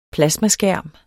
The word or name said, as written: plasmaskærm